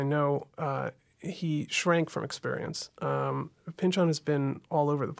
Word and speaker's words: Pynchon: Wait, so the correct pronunciation is Pynchon, not Pynchon.